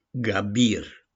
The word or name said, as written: GABIR